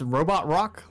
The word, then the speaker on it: Robot Rock: It's Robot Rock, like a Daft Punk song.